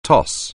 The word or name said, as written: toss